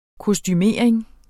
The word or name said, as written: kostumering